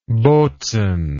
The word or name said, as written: bozen